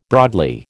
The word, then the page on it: broadly: American English